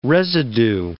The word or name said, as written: residue